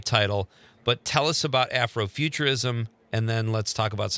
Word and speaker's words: Afrofuturism: Tell me if I'm saying it right. Afrofuturism. – Yes, you are saying it absolutely right.